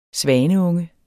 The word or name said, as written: svaneunge